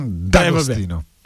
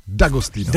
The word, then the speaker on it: D'Agostino: A Gigi D'Agostino? – D'Agostino. – Jak? – D'Agostino. – D'Agostino, dobře.